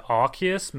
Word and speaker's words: Arceus: Is it Arceus? – I feel like they changed it at some point to Arceus, maybe just because they didn't want Arce to be in the title of their game.